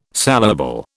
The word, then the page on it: saleable: British English